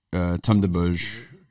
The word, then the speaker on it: Tome des Bauges: Like, Tome de Bauge, you see it? – Yeah. – Like, Place des Vosges. – Yep. – But spelled totally but differently.